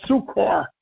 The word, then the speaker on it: succour: Not sugar, not sukkah, not sukkar. Sukkah, probably sukkah, meaning, of course, it means help, for aid, assistance, and help. Sukkah. I may have pronounced it sukkah. I'm sorry about that. The word is probably properly pronounced sukkah. S-U-C-C-O-U-R. Okay?